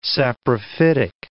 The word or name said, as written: saprophytic